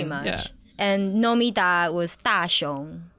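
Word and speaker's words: Da Xiong (大雄): So with the character names, we just go by whatever kanji they use, how that particular kanji is pronounced in Chinese. So Nomida would be one of the kanjis, and in Chinese, we pronounce it Xiong.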